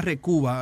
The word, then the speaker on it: Recuva: Y y un programa gratis que se llama Recuba. Hay varios, pero este se escribe R-E-C-U-V-A.